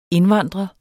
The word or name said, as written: indvandre